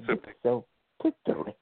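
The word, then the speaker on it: Mxyzptlk: Well, how do you pronounce it? – Mitsplik. That's how I say it the right way. Mitsplik.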